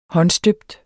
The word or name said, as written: håndstøbt